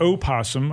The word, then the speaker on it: opossum: Opossum can be pronounced with its first syllable either voiced or silent.